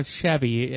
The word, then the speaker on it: Sheave: It's spelled S-H-E-A-V-E, like heavy with an S. – No, I haven't. But I remember you bringing it now. Chevy as in heavy with an S.